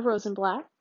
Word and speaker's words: Rosenblatt: My name is Amanda Rosenblatt.